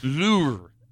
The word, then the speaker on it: Louvre: It is pronounced Louvre. – Louvre. – So there is a R at the end, but it's a soft R.